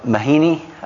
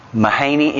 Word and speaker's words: Mahaney: I was reading this week from a uh, director, Mr. Mahaney, I believe is the way you say his name. Mahaney, M A H A N E Y.